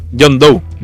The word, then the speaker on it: John Doe: Doe, no me acuerdo cómo, se, cómo lo pronuncian. – John Doe. John Doe, se pronuncia.